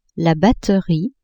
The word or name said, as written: batterie